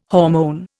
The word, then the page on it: hormone: Indian